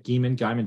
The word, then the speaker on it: Geiman: Another guy that joined at semester, Tyler Geiman, I believe is how you say it. Geiman, G-E-I-M-A-N.